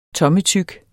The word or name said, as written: tommetyk